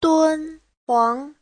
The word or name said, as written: dun1huang2